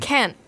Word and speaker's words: Cannes: There's three extra letters that aren't pronounced there. Come on, yep. France. Okay, so she... – Is it C-A-N-N-E-S? – C-A-N-N-E-S. – Yep, Ken. – Ken.